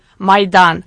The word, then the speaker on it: Maidan: How do you pronounce it? – Maidan.